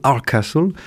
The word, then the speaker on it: Hardcastle: Alors, il paraît qu'il ne faut pas prononcer le T en anglais, donc on va dire Paul Hardcastle, qui a eu son heure de gloire dans les années 80.